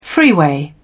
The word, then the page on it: freeway: British English